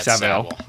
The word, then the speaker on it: Savile: Yeah, Savile. Yeah, they yeah, said that's Savile. That's the way the Brits pronounce it.